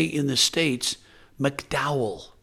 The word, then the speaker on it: McDowell: And it would be pronounced McDowell in Northern Ireland.